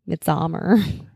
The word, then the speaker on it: Midsommar: Midsommar.